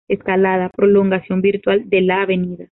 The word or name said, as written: virtual